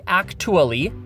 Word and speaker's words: actually: But if we look at a word like actually, the T in actually is actually pronounced more like a CH. I don't say actually.